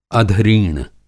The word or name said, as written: अधरीण